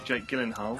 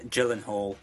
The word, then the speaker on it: Gyllenhaal: Be careful with that pronunciation. You will Gyllenhaal. – Get your, Gyllenhaal. You will get your head snapped off in certain circles if you mispronounce that.